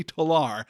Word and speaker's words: Tolar: He really like stresses that terminal syllable on his last name, Avery Tolar.